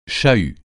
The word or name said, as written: chahut